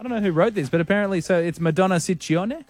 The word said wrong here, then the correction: Ciccone: Well, in Italian, it works the other way. So the double C's are hard C's and the singular C's are soft C's. – So Ciccione. – Ciccone. – Ciccone. Madonna Ciccone. God, why'd she drop that? That's good. It's Ciccone. Anyway, it's Freedom Fridays.